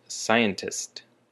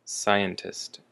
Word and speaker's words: scientist: Our next word is scientist. Scientist. And the emphasis is on the first syllable, scientist.